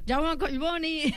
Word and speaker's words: Jaume Collboni: No, Jaume. Jaume Collboni.